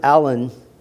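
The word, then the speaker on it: Alleine: William Alane, or Allen, as it's sometimes pronounced, wrote The State of the Church in Future Ages.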